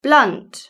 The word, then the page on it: blunt: British English